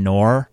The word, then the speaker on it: Noor: So, yeah, I want to give a shout out to my friend Noor for listening. She just texted me that she's listening. And if you're wondering how to pronounce that name, it's like Nora with no at the end.